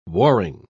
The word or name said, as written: warring